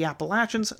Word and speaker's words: Appalachian: As you might have guessed from that last sentence, I am from the American South, which means, of course, that I pronounce the name of the mountain range Appalachian, which is how it is supposed to be pronounced, and is pronounced by the people who live there.